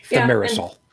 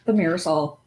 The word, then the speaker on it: thimerosal: And theramosal, whatever that's pronounced. – Thimerosal. Yeah, Thimerosal.